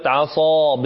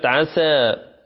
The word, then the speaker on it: عصى: هنا قبل أن تلاوة انتظروا، هنا لابد من بيان كلمة عصى بالصاء، ولا نقول يعني يعني لا تشدد عليها ولا تستعليها فتكون تشابه لكلمة عسى، عسى، فلا بد من نطق الصاد صحيحة والإلا يغير هنا المعنى.